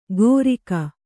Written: ಗೋರಿಕ

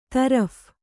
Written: ತರ್ಫಿ

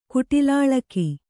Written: ಕುಟಿಲಾಳಕಿ

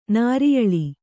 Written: ನಾರಿಯಳಿ

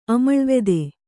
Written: ಆಮ್ಲವತಿ

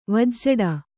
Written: ವಜ್ಜಡ

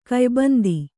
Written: ಕಯ್ಬಂದಿ

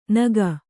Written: ನೆಕ್ಕು

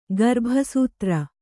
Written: ಗರ್ಭಸೂತ್ರ

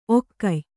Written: ಒಕ್ಕೆಯ್

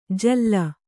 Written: ಝೂಲು

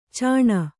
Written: ಚಣ್ಣ

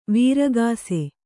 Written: ವೃಕ್ಷ

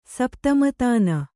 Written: ಸಪ್ತಮತಾನ

